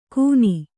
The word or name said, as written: ಗುನಿ